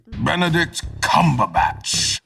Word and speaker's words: Benedict Cumberbatch: Benedict Cumberbatch.